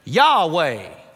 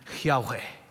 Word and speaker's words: Yahweh: Again, in North Carolina, we would pronounce it Yahweh. The Hebrews would approach it differently. There would be more of that rough breathing in there.